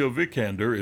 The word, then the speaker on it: Vikander: Alicia Vikander is Vikander. – Vikander.